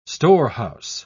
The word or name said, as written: storehouse